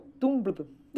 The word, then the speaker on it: Tumblr: É o Tumblr.